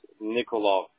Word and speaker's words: Nikolov: It's spelled H-R-I-S-T-O-V. – Thank you so much. Perfect. – And then last name is Nikolov. – Thank you so much. – N-I-K-O-L-O-V.